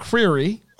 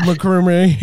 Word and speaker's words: McCreery: McCreery. Last, McCreery. How do you not know how to say that? I always say McCormick, McCormick, uh, McGasha.